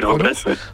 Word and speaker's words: Robles: Il y a un parti qui s'appelle l'Action Populaire, avec comme grande figure, il y a Angel Herrera et Gil Robles, je sais pas comment on prononce, Robles,